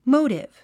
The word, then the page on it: motive: móutiv; モウティブ